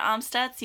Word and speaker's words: Amstutz: And I'm Sandra Amstutz.